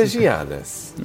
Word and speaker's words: Giannis: It's Giannis. I honestly just feel like His name is Giannis. You know that, right? – It's Giannis. It's a Giannis.